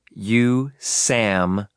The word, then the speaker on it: USAMRIID: Army Medical Research Institute of Infectious Diseases, abbreviated USAMRIID, and pronounced USAMRID, which ran Maximum Containment Laboratories.